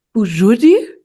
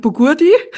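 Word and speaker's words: Bougiourdi: Die Aussprache des neuen Trainernamens hat mitgeholfen, weil weiß, dass das G im Griechischen ja nicht gesprochen wird. – Nee, mein Lieblingsessen beim Griechen ist Bujudi. Und die schreibt man mit G in der Mitte. Und ich habe früher immer gesagt, ich hätte gern einen Bugurdi.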